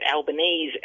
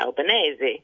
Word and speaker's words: Albanese: He lived in inner Sydney, and they just pronounced it Albanese at that stage, and he quickly became Albo among his mates. But the Italian community around the area where he ultimately became a politician and represented started to sort of say to him, hang on, you know, it shouldn't be Albanese, it should be Albanese.